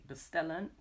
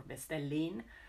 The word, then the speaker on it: bestellen: For example, if we want to say to order, we say bestellen. We write bestellen, but we don't really say bestellen or bestellin, but we say bestelle.